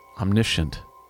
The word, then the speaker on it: omniscient: I always liked the idea as well that I didn't know when I was writing the record that it's pronounced omniscient. I think it's pronounced that. Omniscient. Omniscient? I didn't know that. I thought it was omniscient. So I had done all these promos for it with my puppet. And I said, I'm Ziltoid, the omniscient. Yes. And then, as the internet tends to do, someone's like, it's actually pronounced omniscient. And I was like, not on his planet it isn't.